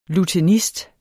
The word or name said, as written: lutenist